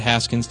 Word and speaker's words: Haskins: contest, former American Idol host Brian Dunkelman and Mr. Balding himself, Dennis Raskins, are the guests. – Oh. – I hope I'm saying that right. Haskins, Dennis Haskins.